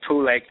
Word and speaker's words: Poulenc: Some people say Poulenc, and some people say Poulenc. And since you said Poulenc, I will continue to do so also.